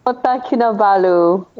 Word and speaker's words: Kota Kinabalu: Kota Kin Kin. I don't want to say it. – Kota, Kota Kinabalu in Borneo Island.